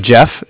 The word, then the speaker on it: Jeff: Jeff, J-E-F-F.